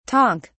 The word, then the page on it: tonk: British English